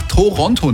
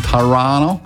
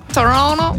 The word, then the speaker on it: Toronto: Und für uns ist es Zeit, natürlich jetzt Tschüssikowski zu sagen an dieser Stelle und werden euch dann morgen mit nach Toronto nehmen, Leute. Oder wie es die Kanadier wahrscheinlich korrekter aussprechen, Toronto. – Toronto. – Ja, Toronto.